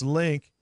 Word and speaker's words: Lynq: That's LYNQ, pronounced Link.